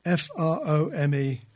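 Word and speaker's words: Frome: Alexa, play Froome FM. – Okay, you heard that. Okay. – Because as you know, Froom is spelt in a pronounced in a particular way. It's not pronounced how it's spelt.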